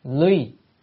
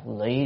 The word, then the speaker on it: ly: Quyến thuộc duyên, cái chữ này đọc là ly, tại vì không phải đọc lụy được.